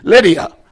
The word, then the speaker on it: Lydia: And a certain woman named Lydda. I'm not saying that right. Lydia. I got it now.